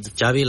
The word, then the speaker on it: Xavi: Xavi like chocolate. – Yes, okay. Thank you very much. – Xavi like chocolate.